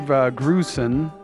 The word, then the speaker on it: Grusin: They fell in love, fell in lust, came to New York in search of a dream, were snatched up by Dave Grusin. Is that how you pronounce his name? – Yeah.